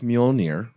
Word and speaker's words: Mjolnir: I saw a theory that said Cap couldn't lift Mjolnir.